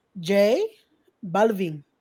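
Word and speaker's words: J Balvin: Jay Blevlin. Those sneakers, them shits is, I don't even know how to pronounce that, right? You don't read it. – Yeah, those are the Jay Balvins. – The who? – Jay Balvin. – Balvin. – Balvin, yeah. He's a reggaetonero.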